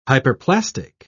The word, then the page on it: hyperplastic: British English